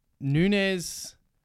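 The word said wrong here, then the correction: Núñez: I'm getting stuck on Nunes because it's actually pronounced Nunez.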